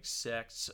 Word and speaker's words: sect: And then the the different like sects of people, not sex, but s- sect.